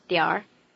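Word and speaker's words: 点儿: 点心 The word for some in this sentence is the toneless syllable, diǎr.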